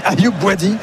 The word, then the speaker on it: Ayoub Bouadi: Je me disais c'est qui Ayoubi, je regardais fiche et je me dis c'est ça, j'avais besoin d'un coup de brumisateur. – Il manquait une syllabe au milieu, quoi.